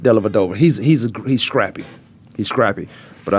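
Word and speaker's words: Dellavedova: That's like Della Dover. – Della Vadova. It's a V.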